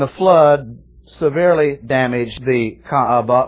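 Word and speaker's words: Kaaba: And therefore, they made an enclosure that was called Ka'aba, K-A-A-B-A-H. In the Semitic languages, almost all the time, every syllable is pronounced. So this is not Ka'aba, but two syllables, Ka'aba, three syllables, right?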